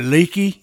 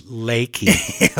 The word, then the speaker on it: Leaky: Is it leaky? – Yeah. It's Lakey. – Okay. – But it's spelled Leaky.